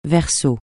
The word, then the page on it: Verseau: francais